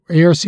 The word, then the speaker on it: ARC: So a word, ARC is a word from the initial letters of affinity, reality, communication, which together equate to understanding. It is pronounced by stating its letters, A-R-C.